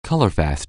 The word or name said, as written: colorfast